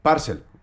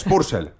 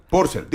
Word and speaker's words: Purcell: Por cierto, Nicholas Jackson, yo estaba harto de Parcel. Dice usted mal Parcel. Es Purcell. Purcell.